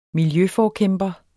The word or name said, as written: miljøforkæmper